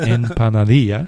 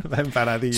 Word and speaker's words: Empanadilla: Empanadilla. – Empanadilla.